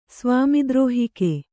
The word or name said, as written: ಸ್ವಾಮಿದುರುಹಿಕೆ